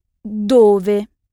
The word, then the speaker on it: dove: Ou, pour parler du lieu où se trouve quelque chose, se dit dove. D-O-V-E.